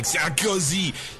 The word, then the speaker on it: Sarkozy: I mean, you got to pronounce it like Sarkozy.